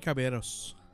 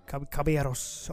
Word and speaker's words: Caballeros: The three Caballeros. – Caballeros.